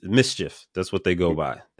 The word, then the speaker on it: MSCHF: MSCHF. Mischief, basically, Word. Is how you say it.